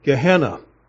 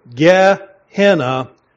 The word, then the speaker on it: Gehenna: Let me pronounce it this way, Gehenna. Let me kind of separate a little bit. Gehenna, or the land or valley of Hinnom.